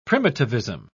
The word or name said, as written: primitivism